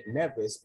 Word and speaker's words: Nevis: A lot of folks call it Nevis, but the pronunciation is Nevis.